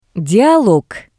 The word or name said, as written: Dialog